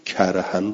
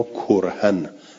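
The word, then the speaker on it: كرها: خیلی خوب، القرائت، قرائت آیه شریف، قرأ حمزه و کسائی، حمزه و کسائی کرهن را کرهن به زم کاف تلفظ کردن،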